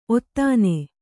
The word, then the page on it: ಔತನ: autana; Kannada